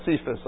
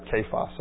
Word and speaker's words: Cephas: Sorry, I say Cephas because someone from Israel once pointed out that when I said Cephas, I was saying it wrong. And that even though it is a Greek word, that they pronounce it kephas.